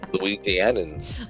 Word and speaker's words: Louisianans: but has been so vociferously defending Louisianians. Is that how you say it? – Louisianans.